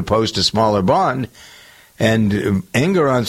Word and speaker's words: Engoron: Engoron, is that how you pronounce his name? – I think it's right. – Engoron, yeah.